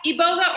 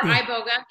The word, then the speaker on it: iboga: Yeah, iboga or iboga. It's pronounced both ways.